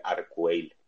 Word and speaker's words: Arcueil: Eh, es que no se pronuncia el nombre francés, pero vamos, se escribe Arcueil.